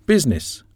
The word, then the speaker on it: business: Business, the I isn't pronounced.